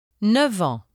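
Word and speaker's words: neuf ans: When 9 precedes the words en and heure, the final consonant is pronounced v. Listen and repeat. – 9 ans, 9 heures.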